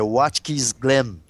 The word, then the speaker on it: Watkins Glen: A Lotus Gold Leaf número 24 cruzou a linha de chegada em Watkins Glen, eu tô com a voz um pouco ruimzinha, mas é Watkins Glen, Fábio, o Fábio pode me ajudar na pronúncia aí. – É isso mesmo, perfeito, quem sou eu para corrigir sua pronúncia? – Obrigado.